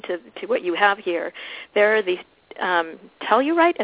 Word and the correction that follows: telluric: Is that what they're saying it right? Currents? Did I say that right? – Oh, the telluric currents, yeah.